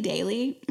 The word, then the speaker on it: Daily: Yes, but my Instagram is Bayday, so B-A-Y-Y-D-A-Y-Y, and my YouTube is Bailey Daily. My name is spelled B-A-Y-L-E-I-G-H, and Daily, which is D-A-I-L-Y.